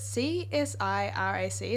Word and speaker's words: CSIRAC: Like we've got Battery Death and then is it C-S-I-R-A-C? Is that how you say it? – Yep.